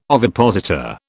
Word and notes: ovipositor: British English

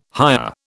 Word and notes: hire: British English

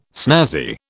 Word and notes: snazzy: British English

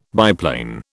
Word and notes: biplane: British English

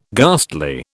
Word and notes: ghastly: British English

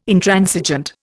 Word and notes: Intransigent: Indian

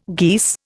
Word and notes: geese: Indian

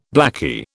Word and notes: blakey: British English

